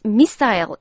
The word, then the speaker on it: missile: 자, 장거리 미사일, CNN 기자는 long range missile 이라고 발음했는데, 네. – 제니퍼는 long range missile 이라고 발음했죠. 자, CNN 기자는 영국식 영어 발음이고요. 제니퍼는 미국식으로 발음했습니다.